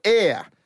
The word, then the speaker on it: Aire: Kylie Jenner, who's the youngest sister apparently, she's revealed her 11-month-old son's name is called Ayre. – Spelt A-I-R-E. – Yep, but it's pronounced A-I-R.